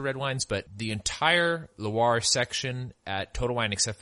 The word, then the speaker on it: Loire: the Loire Valley. I always want to say Luray, but I think it's Loire.